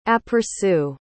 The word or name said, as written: aperçu